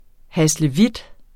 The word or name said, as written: haslevit